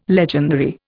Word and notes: legendary: Indian

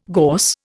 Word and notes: gorse: Indian

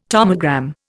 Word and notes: tomogram: Indian

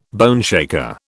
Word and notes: boneshaker: British English

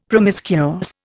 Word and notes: promiscuous: Indian

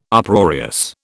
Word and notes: Uproarious: British English

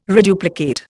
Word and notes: reduplicate: Indian

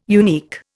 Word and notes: unique: Indian

